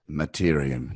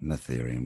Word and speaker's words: Mattereum: It's Mattereum, not Matherium.